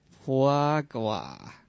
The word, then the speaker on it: foie gras: So say it with us. Foie gras. – Foie gras. – Foie gras. – It's very buttery. Melts on your mouth like a bloody goose liver. – It's spelled F-O-I-S, and then the second word is G-R-A-S.